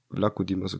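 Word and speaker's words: Vlachodimos: Vlacho Dimos, okay, so spricht man aus.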